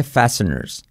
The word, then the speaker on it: fasteners: Fasteners, fasteners. Fijaos que fasteners, la T no la pronuncia, ¿vale? Fasteners, con letra clave R.